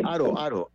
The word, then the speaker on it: Haro: Aro se escribe H-A-R-O, ¿verdad? – Sí, Aro, H-A-R-O.